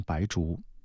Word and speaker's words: 白术: 你看，比如说我们的那个白术，白术这个字啊，可能很多人不知道它怎么念啊，念成了算术的术啊，算术的术，一个木上面一个点，中药里面念白术，有白术还有炒白术，为什么是有炒白术，它不用白术呢？